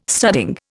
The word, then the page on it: studding: Indian